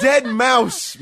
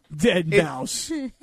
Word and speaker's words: deadmau5: It's Dead Mouse, man. Dead Mouse. – Oh, man. – All right, hold on a second. It's spelled DEADMAU5. That's Dead Mouse? – Yeah. It's just creative.